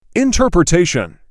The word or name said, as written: interpretation